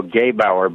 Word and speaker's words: Gebauer: And if you go back to Silas Newton and Leo Gebauer, by the way, is how you say that.